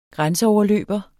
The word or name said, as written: grænseoverløber